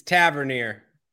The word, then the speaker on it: Tavernier: Or do you want like a Tavernier in as maybe your number one midfielder kind of a thing? – Is it Tavernier or Tavernier? – It's Tavernier.